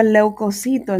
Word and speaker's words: leucocito: Esta palabra leucocito es una palabra llana, no se acentúa en la penúltima sílaba debido a que termina en vocal.